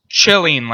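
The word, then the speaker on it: Celine: Isn't it Chilene? – Sorry? – Hagbard Chilene? – Oh, okay. Chilene? – Chilene, like a Chilling.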